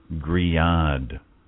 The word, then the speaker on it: Grillade: It's not Grill Aid. It's spelled Grill Aid, G-R-I-L-L-A-D-E. It's actually pronounced Griad. That's like G-R-E-E-Y-A-H-D, phonetically. Griad. So my apologies to my compadres. Griad. It's Spanish, apparently.